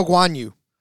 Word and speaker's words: Guanyu: Guanyu, is that how you say it? Joe Guanyu.